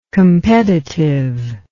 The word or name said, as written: competitiveness